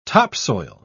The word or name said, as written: topsoil